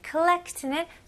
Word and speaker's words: collect: 여기서는 오 발음이지만 호 이런 느낌 아니고 collect. 여기는 거의 A 느낌으로 하셔도 돼요. 예를 들어서 누구한테 call 할 때 collect. Collect. A하고 U 사이인 것 같아요.